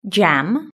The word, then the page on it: jam: british-english